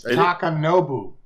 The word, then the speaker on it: Takanobu: It was directed by Takanobi Mizuno. Takanobi Mizuno. – Yeah. – Do you like my pronunciation? – It's Takanobu.